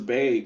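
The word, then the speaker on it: Bea: Bay or Bia. I don't know how to say her name. – That girl's Bay.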